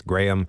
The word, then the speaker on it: Graham: but that's not how you pronounced that name. It's Graham.